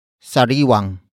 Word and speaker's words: sariwang: Sariwang. Sariwang. Sariwang. Sariwang is spelled as S-A-R-I-W-A-N-G. It consists of sariwa, which is an adjective root word meaning fresh, plus the ng linker to introduce what object is fresh, And that object is spelled as I-S-D-A.